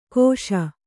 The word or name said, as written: ಕೋಷ